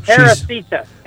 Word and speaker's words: Teresita: Teresita. Teresita. Let me spell it for you. T-E-R-E-S-I-T-A. – Teresita. Love it. Just love the sound of it. – Oh, like Teresa, but with some extra.